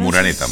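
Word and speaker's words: Moreneta: Murenita, ¿no? Así se pronuncia. – Muraneta. – Muraneta, sí, bueno, casi.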